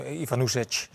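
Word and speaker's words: Ivanušec: keurig van Feyenoord doorgestuurd hoe je dat moet uitspreken. Ivan Ivanusec. moet, je, moet je zeggen.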